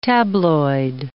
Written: tabloid

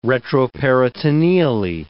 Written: retroperitoneally

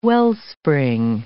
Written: wellspring

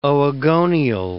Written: oogonial

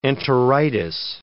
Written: enteritis

